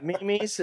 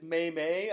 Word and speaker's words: meme: Memes is the meme is the right word. Some people have pronounced it may-may. I believe May-may. Bryce Harper is one of those, but it is meme.